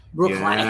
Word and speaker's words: Bruichladdich: You guys want to talk about Bruichladdich? – Bruichladdich.